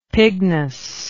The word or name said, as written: pignus